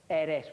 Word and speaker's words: Eris: It's E-R-I-S. So please don't take it out on Eris.